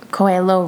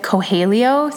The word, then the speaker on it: Coelho: My mom informed me that I was pronouncing Paulo Coelho wrong. So I think I said Coelho. So mom, this is for you. It is Coelho.